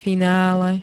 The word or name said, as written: finále